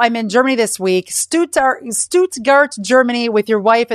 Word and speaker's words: Stuttgart: You live in Stuttgart, Germany. Am I saying that right? S- Stuttgart. – Not even close. Stuttgart.